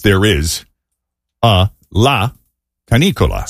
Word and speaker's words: canicola: And so a prolonged oppressive heat wave, sort of, you know, we call it the dog days of summer, is La Canicola. And it's spelled C-A-N-I-C-O-L-A.